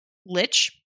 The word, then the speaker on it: lich: What is L-I-C-H? Is it leech? Lick? – Lich? It's pronounced lich.